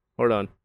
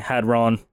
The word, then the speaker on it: Hadron: It's definitely Hadron. – It is pronounced Hadron. – Hadrogenized oils? – It's not a Decepticon, it's Hadron.